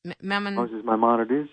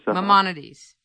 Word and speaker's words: Maimonides: Moses Maimonides. – Maimonides. – A very interesting figure. He's known as the Rambam in Hebrew, Rabbi Moshe ben Maimon, Moses the son of Maimon, or Moses Maimonides.